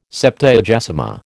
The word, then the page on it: Septuagesima: American English